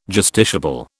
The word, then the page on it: justiciable: British English